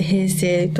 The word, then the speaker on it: 平西: 平成最後に、関西の西でできた劇団ですので、はい、平西と書いて平成と読ませる平成丸プロという劇団になっております。